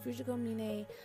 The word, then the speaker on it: Fujiko Mine: I've only watched Fu- Mini, Minio, Mine, Fujiko Mine Sorry, I completely butchered that. Fujiko Mine, uh, that whole story with Lupin, but I'm actually interested in watching Lupin from the beginning.